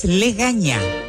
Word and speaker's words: legaña: Es legaña, con E. Búsquelo. Legaña.